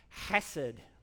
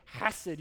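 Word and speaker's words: hesed (חסד): And it's pronounced hesed, okay? Hesed. You got to use that hangy down thing in the back of your throat to say it like. Everybody say hesed. Kind of rasp it on the front there, yeah.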